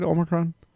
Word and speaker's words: Omicron: Is that how you say it, Omicron? – Yep.